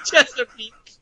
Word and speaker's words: Chesapeake: How did you say that again? Chesapeake. – The Cheesecake Bay Bridge Tunnel. It looks like cheesecake or cheesecake. Chesapeake. Chesapeake. Chesa what now? – Chesapeake.